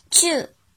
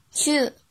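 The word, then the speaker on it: ちゅう: 音读的时候读作 chu chu chu，比如注射秋夏秋夏秋夏。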